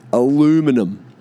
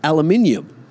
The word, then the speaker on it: aluminium: Aluminum. Yeah, down just working at the aluminum mine. Completely hijacked the word. Aluminum. It's aluminium. Aluminium. Not aluminum.